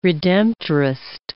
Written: Redemptorist

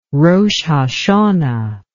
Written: rosh hashanah

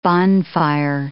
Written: bonfire

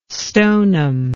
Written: stoneham

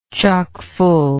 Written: chockfull

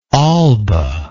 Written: alba

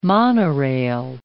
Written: monorail

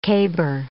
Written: caber

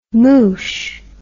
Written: moosh

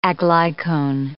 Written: aglycone

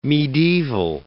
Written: medieval

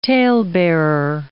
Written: talebearer

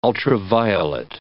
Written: ultraviolet

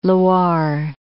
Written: Loire

